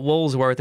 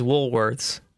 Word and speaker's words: Woolworths: It's not Woolsworth, it's Woolworths.